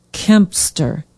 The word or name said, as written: kempster